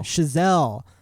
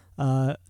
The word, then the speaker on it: Chazelle: Chazelle. – Chazelle, well known for Whiplash and La La Land.